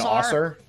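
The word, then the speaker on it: Ausar: So it's how do you pronounce the two Thompsons' first names? – Amen and Osser.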